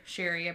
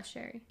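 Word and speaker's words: Sherre: So after Alex calls the police, he calls Shannon's sister, Sherry, I believe is how you pronounce it. It's S-H-E-R-R-E. – Yeah, Sherry.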